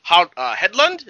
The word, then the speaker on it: Hedlund: So let's talk about Sam Flynn, Kevin Flynn's son, played by Garrett Hedlund? Is that how you pronounce his name? – Yes. Yeah. – Garrett Hedlund,